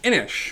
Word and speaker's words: Inis: All right, so I am going to be reviewing Inish, which is spelled, if you're looking for it on the internet, I-N-I-S, but I am told it's pronounced Inish. It's an Irish word.